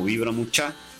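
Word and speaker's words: Vibra Muchá: Estamos igual como Vibra Mucha, con acento en la última A, y ahí van a poder encontrar la música que nosotros realizamos desde hace casi 20 años atrás, ¿sí? – Nada más y nada menos, ¿no?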